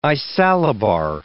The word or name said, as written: isallobar